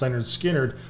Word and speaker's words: Lynyrd Skynyrd: And one of the reasons I've never heard pronounced Lynyrd Skynyrd is because half of these songs get played on the radio all the time.